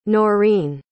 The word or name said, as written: Norene